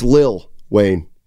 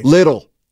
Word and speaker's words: Lil Wayne: I think it's Lil Wayne. – What'd I say? – Little. I thought you had your ear to the streets. I think that's the way it's pronounced.